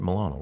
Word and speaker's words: Milano: Milano, right? – Yeah.